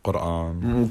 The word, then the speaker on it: Quran: So if you ever want to ask a bit about the Quran, and he can pronounce it better than me, please Quran.